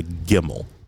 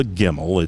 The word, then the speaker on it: Gimmel: draw the image of the Gimmel. It's very easy to draw this third level of the alphabet, the Hebrew alphabet. And by the way, it's pronounced with a G as in girl.